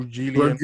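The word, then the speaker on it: Reguilón: Reguilon.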